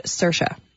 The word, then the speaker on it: Saoirse: Well, I'll give you the spelling. S-A-O-I-R-S-E. – All right. – And people are saying that it's pronounced Saoirse.